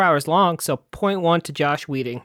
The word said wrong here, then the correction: Joss: It's not Josh. It's Joss. OSS.